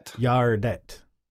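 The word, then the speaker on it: Gärdet: Is it Gardette or Yardette or how is that pronounced? – Yardette.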